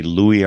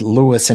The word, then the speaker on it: Louis: My big question is, am I saying it wrong when I say Louis Armstrong? – You're saying it right, depending on who was deciding what nickname to stick him with. His mother preferred Louis and insisted that was the only right name.